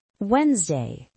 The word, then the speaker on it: Wednesday: Wednesday, Este é tricky. Pronuncia-se Wednesday. O D é praticamente mudo.